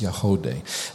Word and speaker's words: Yagody: It's a spectacular performance, Y-A-G-O-D-Y, but it's pronounced Yahode.